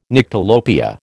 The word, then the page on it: Nyctalopia: American English